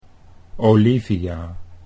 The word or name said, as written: Olivia